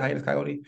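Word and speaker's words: Hiatus Kaiyote: Hiatus coyote? – That's correct.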